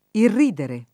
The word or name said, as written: irridere